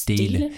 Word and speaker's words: Stele: Spricht man das so aus? – Stele. Es waren zwei Stelen.